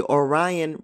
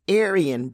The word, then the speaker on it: Aryan: Kat repeatedly mentioned the Orion Brotherhood before I realized that she was speaking of the Aryan Brotherhood.